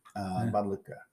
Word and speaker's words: Badalucco: How do you pronounce the name? Badalucco? – Badalucco. – Okay. – Badalucco, yeah. – Yeah. – L-U-C-C-O. Yeah, not Lucci.